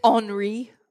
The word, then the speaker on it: Henri: I believe it's Henri. – Oh, what am I? – Really? – How it's spelled. – Oh, yeah, same. I look at like, oh, Henry. And then I hear the news reporter's like, Henri.